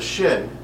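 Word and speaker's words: Shin (שין): And the Aleph Bet, the last reverse of it is, what do you say, Tuf and Shin, right? Is that how you say it? – Are you talking about the last two letters? – Yes. – Yeah, are Tuf and then Shin. Yeah.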